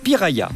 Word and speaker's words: piraya: Dans leur langue, le tupi-guarani, cela se prononce « piraya ». Puis, « piraya » est devenu « piranha » en portugais.